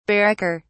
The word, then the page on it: barracker: British English